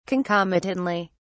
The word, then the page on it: concomitantly: British English